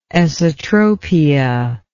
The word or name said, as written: esotropic